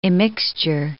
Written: immixture

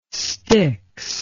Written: Styx

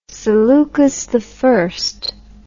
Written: seleucus i

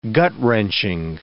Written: gut-wrenching

